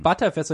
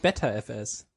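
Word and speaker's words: Btrfs: Genauso wie die ButterFS ist ja auch ButterFS und nicht BetterFS.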